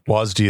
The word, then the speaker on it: WASD: Also, I think there was a debate about the pronunciation of WASD when WASD is how you say it. That's the that's there's no debate.